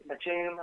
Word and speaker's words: Batum: Nick Batum, I think is how you pronounce his name. – Right.